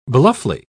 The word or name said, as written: bluffly